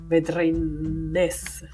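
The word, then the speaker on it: Védrines: Oppure Jules Vedrin, Vedrines, si scrive Vedrines,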